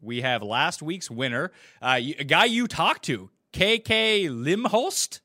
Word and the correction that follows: Limbasut: It is pronounced Limbasut, believe it or not.